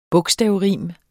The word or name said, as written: bogstavrim